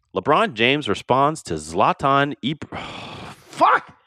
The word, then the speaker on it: Ibrahimovic: But I mean, Ibrahimovic.